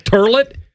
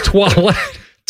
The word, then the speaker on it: Toilette: Yeah, just what did you pronounce it? Toilet? – Eau de Toilette? – It's Toilette.